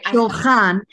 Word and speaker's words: Shulchan: How do I say it? Shulchan. It's a Hebrew word. The Hebrew word for Shulchan is table.